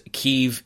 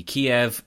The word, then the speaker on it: Kyiv: Now, I just want to say, for the sake of pronunciations, Kyiv is the Ukrainian pronunciation of the city Kiev, but I've always called it Kiev because I like chicken Kievs, and that's just the way I've always said it.